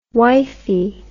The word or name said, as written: wifie